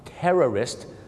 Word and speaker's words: terrorist: สองคำนี้มีเส้นบางๆอยู่นะครับในการออกเสียงคือพยางค์สองพยางค์ tourist นักท่องเที่ยวกับ terrorist สามพยางค์ครับ